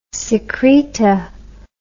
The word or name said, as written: secreta